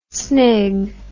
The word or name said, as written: snig